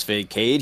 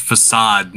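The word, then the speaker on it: façade: To bolster his facade, he claims his special gift is the result of a car accident. – That word is pronounced facade.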